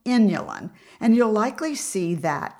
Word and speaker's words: inulin: Inulin. It's spelled I-N-U-L-I-N, inulin.